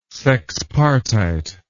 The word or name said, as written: sexpartite